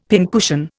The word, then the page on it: pincushion: Indian